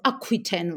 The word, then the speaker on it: Aquitaine: Eleanor of Aquitaine or Aquitaine rather. I always tend to pronounce uh, the French uh, names the French way.